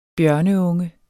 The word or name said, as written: bjørneunge